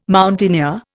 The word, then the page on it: mountaineer: Indian